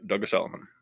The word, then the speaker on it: Douglas Elliman: Yep, Douglas Elliman.